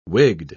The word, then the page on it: wigged: British English